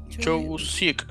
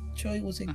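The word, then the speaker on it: Choi Woo-shik: Uh, ¿Cómo se pronuncia? ¿Choi Woo Sik? – Choi Woo Sik, sí. ¿No? – Choi Woo Sik.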